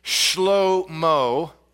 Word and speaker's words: Shlomo: Now, if you've taken any amount of Hebrew, you would know that the way that we pronounce his name, Solomon, is, well, it's not how it's pronounced in Hebrew. His Hebrew name is Shlomo, which is one of the only Hebrew words I remember from seminary.